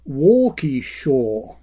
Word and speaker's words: Waukesha: The Wisconsin city that I referred to in a recent radio derb is pronounced by locals as Waukesha, as opposed to my attempt, which was something like Waukesha.